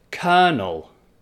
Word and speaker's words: colonel: So a colonel is like a captain or something in the army, but the spelling is C-O-L. O-N-E-L. So that first C-O-L has like, the L is not there and it's just colonel, you see.